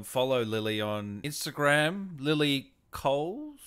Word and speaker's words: Cowles: It's a silent W. There is silent a W, w. in there. – My father always used to say, in regards to people saying cowls, he'd say, would you say Camilla Parker Bowles?